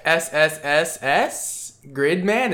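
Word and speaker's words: SSSS.Gridman: We got SSSS Gridman.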